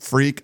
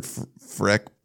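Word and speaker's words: Friekh: So Laith Freak, I think it's Freak, but I've heard Freck pronounced too. It's F-R-I-E-K-H.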